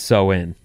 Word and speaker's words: Samhain: Which they say is pronounced so-in.